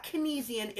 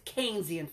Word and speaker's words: Keynesian: Keynesian economics, not Keynesian, it's Keynesian, for God's sake. And everybody always mispronounces it, and they always try to sound smart. Like, you're a moron. It's Keynesian, not Keynesian.